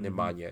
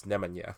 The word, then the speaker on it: Nemanja: But like, Nemanja Bjelica, that's the proper pronunciation. It's not Nemanja, it's Nemanja.